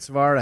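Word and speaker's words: Syverud: I've been now in Kent Savard, I think I said that right.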